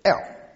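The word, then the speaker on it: El (אל): 这个神这个字哈，希伯来语普通的神单数的就是 El E-L，阿拉伯人就念成阿拉，同个字，所以希伯来文的 L 是阿拉伯人的阿拉或者安拉，所以可能经用阿拉单数的那个字，就是这边所用的神。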